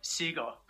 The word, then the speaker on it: Segal: Segal, but Segal sounds Segal. A lot cooler, so.